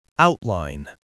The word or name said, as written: outline